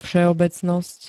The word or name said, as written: vseobecnost